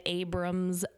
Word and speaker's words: Abrams: And if you want to hit up Rachel, which is me, you can find me at Abrams Records.